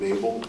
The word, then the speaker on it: Mable: Sure, Lieutenant Ethan Mabel, M-A-B-L-E, from Danbury Police Department.